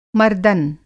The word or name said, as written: मर्दबाज़